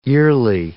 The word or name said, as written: yearly